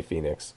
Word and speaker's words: Fenix: It's spelled Fenix, but we all say Phoenix. – I'm sure it's Fenix. – And I'd like to say Phoenix. – I say Phoenix too, but phonetically, it absolutely is Fenix.